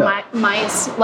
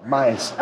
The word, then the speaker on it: maíz: No, maíz, like, corn, de la vida, corn is life. – Oh, maíz. – I have very terrible pronunciation, very, very southern. – Yes. – M-A-I-Z. – Yes. – So it's in Spanish.